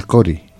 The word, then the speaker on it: Cori: Hay hermanas y hermanos que no pueden pronunciar la palabra, por ejemplo, Cori. ¿Y por qué se lo cambiaron? Se lo cambiaron por Curi.